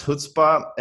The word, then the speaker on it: chutzpah: By the way, if you're wondering what chutzpah means, it's a noun and it's pronounced chutzpah.